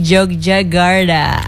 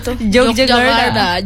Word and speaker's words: Yogyakarta: Interiornya itu di Studio Jakarta, eksteriornya di Jogja Garda. – Di mana? mana? – Yogyakarta.